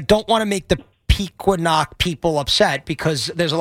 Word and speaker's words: Pequannock: It's Pequannock, but even funnier, Pequannock, but I grew up in Secaucus.